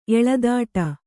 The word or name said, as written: ಎಳೆದಾಡು